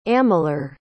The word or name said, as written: amalur